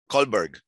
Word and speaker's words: Colberg: Colberg.